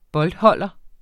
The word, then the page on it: boldholder: [ -ˌhʌlˀʌ ]; [ -ˌhʌlʌ ]